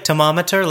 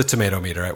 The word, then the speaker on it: Tomatometer: I would say like Tomometer, like a thermometer. – Tomometer. Oh. – But it is the tomato meter.